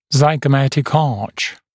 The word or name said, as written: zygomatic arch